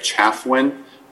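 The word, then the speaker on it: Chafuen: My name is Greg Chaffwin. That's spelled C-H-A-F as in Frank, U-E-N.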